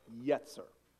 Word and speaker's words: yetzer: So the clay at this point is actually in Hebrew called yetzer. So I think we have a screen for it. If you can imagine a southerner saying yes sir, you're not far from pronunciation.